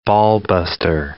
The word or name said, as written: ballbuster